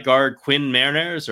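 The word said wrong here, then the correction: Meinerz: His last name is pronounced Miners.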